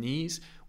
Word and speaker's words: knees: Also remember the K on knees, we don't pronounce, so we don't say knees, although I do think that sounds pretty cool, the bee's knees, but it's just the bee's knees.